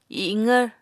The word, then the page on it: iqvr: Apatani